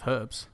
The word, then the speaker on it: herbs: Yeah, but there's a H in front of herbs. – Yeah, but it's a silent H.